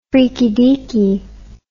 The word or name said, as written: freaky deaky